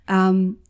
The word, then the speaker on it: Wombat: I hope I have pronounced that word correctly. – Yes, correct. Wombat.